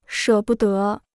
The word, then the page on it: 舍不得: shě bu de